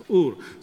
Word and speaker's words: Ur: You know, we can pronounce or Ur, doesn't matter. Yeah, it's Ur.